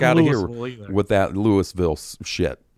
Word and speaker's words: Louisville: Aaron from Louisville, Kentucky. You hear that? I said it right. Louisville.